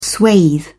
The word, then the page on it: swathe: British English